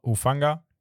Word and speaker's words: Hufanga: Auch von den 49ers, Talanoa Hufanga. Auf 77 Geno Smith von den Seahawks. Digga, auf Hufanga hast du besser ausgesprochen, als ich gedacht hätte, Digga.